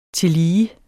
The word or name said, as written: tillige